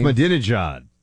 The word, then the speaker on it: Ahmadinejad: Honestly, I think, Buck, I don't know if you saw this story, that Israel thought, and I can't pronounce his name, Mahmoud Ahmadinejad or whatever you pronounce his name. – Ahmadinejad.